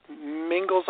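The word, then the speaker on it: Mingils: For those people that need the right spelling, it's M-I-N-G-I-L-S. That's M-I-N-G-I-L-S. It might sound a little bit different. It sounds like Mingles like Pringles, but it's spelled a little bit differently.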